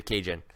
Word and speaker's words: Kajan: I'm going to stick to Kajan. It rhymes with Raging.